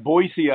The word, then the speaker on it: Boise: Boise, Idaho.